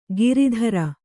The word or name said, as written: ಗಿರಿಧರ